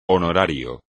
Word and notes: honorario: espagnol

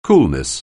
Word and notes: coolness: anglais

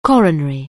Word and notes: coronary: anglais

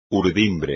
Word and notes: urdimbre: espagnol

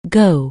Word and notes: go: anglais

